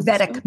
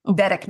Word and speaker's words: Vedic: Or Vedic meditation. – Oh, M with an M? – M-E-D? V as in Victor, Vedic meditation. – Okay. – Yeah. – Okay. – V-E-D-I-C.